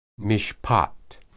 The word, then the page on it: mishpat: Hebrew